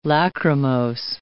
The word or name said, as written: lachrymose